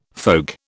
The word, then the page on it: folk: British English